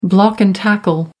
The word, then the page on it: block and tackle: American English